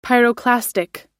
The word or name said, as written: pyroclastic